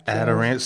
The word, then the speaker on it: Adirondack: They got the Adirondack. Am I saying that right? – Adirondack.